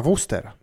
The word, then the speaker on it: Worcester: Worcester ir izrunā kā Vustera. Ah.